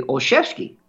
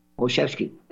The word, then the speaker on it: Olszewski: and I believe that pronunciation in Poland would be Olszewski, S-Z-E-W-S-K-I would be Szewski. Olszewski.